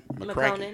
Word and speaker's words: McConaughey: McConaughey. No, you said it right.